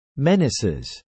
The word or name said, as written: menaces